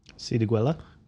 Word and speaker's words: Siriguela: Even the name is quite hard to pronounce if you're not a Brazilian Portuguese native speaker, which is called Siriguela. – Siriguela? – Oh, pff, I was proven wrong. You said it quite well.